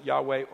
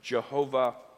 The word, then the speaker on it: Yahweh: We pronounce it Yahweh or Jehovah.